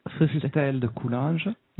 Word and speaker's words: Fustel de Coulanges: But in any event, there's a great little book called The Ancient City by Fustel de Coulanges.